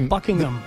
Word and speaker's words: Buckingham: Y de hecho, en inglés perfecto, el H medio que se es medio silente. Buckingham.